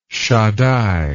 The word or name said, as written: shaddai